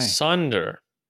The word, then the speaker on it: Sonder: I believe it's pronounced Zonda, but it's spelled S-O-N-D-E-R, Sunder. – Okay. – Sunder. – Yeah. Sunder. Like you're under a sun, but not the bright sun.